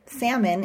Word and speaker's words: salmon: For example, salmon is pronounced without the L sound.